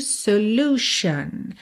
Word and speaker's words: solution: Em solution, nós não temos U, tá? É U mesmo, solution.